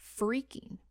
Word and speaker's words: phreaking: The term for phones is actually phreaking, P-H-R-E-A-K-I-N-G, pronounced like freak, but spelled with a P-H.